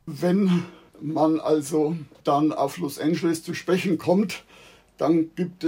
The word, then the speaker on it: Los Angeles: Los Angeles wird nur mit Ä ausgesprochen, also man kann sich als Gedächtnisstütze im deutschen Anfangslaut von Äpfel als Hilfe nehmen. Eine andere Aussprache ist eigentlich da immer falsch. Es heißt zwar Angel im Englischen, daher kommt auch oft die falsche Aussprache. Dennoch beginnt der Anfangslaut von Angeles nicht mit A, sondern mit Ä.